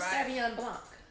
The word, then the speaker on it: Sauvignon Blanc: It's a Sauvignon Blanc.